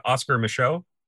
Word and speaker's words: Micheaux: I want to talk about, please correct me if I'm pronouncing his name incorrectly, but Oscar Michaud, is that? – You're correct.